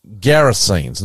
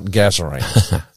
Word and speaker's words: Gerasenes: And if you're with us last week, Hunty and I went to the region of the, and I'm going to say it properly, Gerasenes, not Gazarenes. Gerasenes.